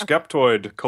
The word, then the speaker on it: Skeptoid: Am I pronouncing Skeptoid. – That right? – Yes, Skeptoid, okay.